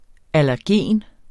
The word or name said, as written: allergen